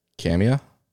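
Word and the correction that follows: Kamiah: That was about the fucking closest. It's Kamiai.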